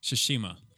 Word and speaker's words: Tsushima: The Ghost of Tsushima.